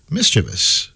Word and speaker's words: mischievous: It's correct pronunciation is... Mischievous. – All right. Well, 0-3 then. – But we often do say mischievous. – So what? But mischievous is how you're supposed to say.